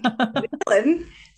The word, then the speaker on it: ghrelin: ghrelin.